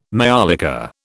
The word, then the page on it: maiolica: British English